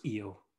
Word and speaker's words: Io: I think it's Io. It's pronounced Io.